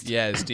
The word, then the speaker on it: Yazd: Yes, Yazd in Iran.